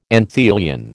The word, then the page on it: anthelion: American English